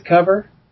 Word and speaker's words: Cover: Yeah, the reason I know the pronunciation is I got another one of his books that he wrote, and it looks like it's his cover, C-O-V-E-R, but it's Cover.